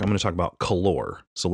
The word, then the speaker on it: Calor: It's C-A-L-O-R. It looks like calorie, but I called it Calor. I found out later, Calor is the way you pronounce it.